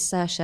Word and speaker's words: Saoirse: I really wanted to be the one to say that the way you pronounce it is Saoirse, and you've taken that away from me now.